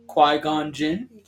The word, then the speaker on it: Qui-Gon Jinn: Master Quinn. – Qui-Gon Jinn. – Yeah, Qui-Gon Jinn. I got your back. Qui-Gon Jinn. – I don't know how to pronounce his name. I thought his name was Quinn.